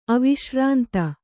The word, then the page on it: ಅವಿಶ್ರಾಂತ: aviśrānta